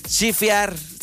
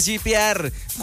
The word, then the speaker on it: shipear: P-O-V. Chifear. ¿Sí se pronuncia así? ¿Chifear? ¿O chipear? Chipear. ¿Chipear? ¿Chipear? Ay, ni siquiera sé cómo se pronuncia. ¡Cállense! Ustedes ahí, los que me están escuchando, también se rieron, pero de tristeza porque ya están grandes. Dice shipear, cuando quieres que dos personas sean pareja.